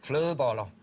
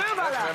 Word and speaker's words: Flødeboller: Flødeboller. – Flødeboller. – Mm. Well, that's good. – Did I say it right? Very good. – Yeah. – Flødeboller.